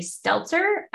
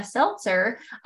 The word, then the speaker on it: seltzer: say stelzer i don't know if it was just because that's when i had my invisalign in and i kind of had a lisp or if i was really pronouncing it that way so a seltzer um, for a while was doing well for me just because my body could process it better and easier.